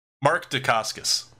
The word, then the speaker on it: Dacascos: Dacascos? – Mark Dacascos. – Dacascos. Oh, yeah. I was pronouncing it wrong.